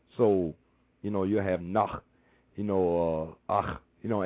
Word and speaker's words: Nacht: N-A-G is Nach. Kui Anach. – Kui Anach. – Kui Anach. – And in German is spelled N-A-C-H-T. Nacht. They say Nacht. We have a more smoother saying. They have a more ach.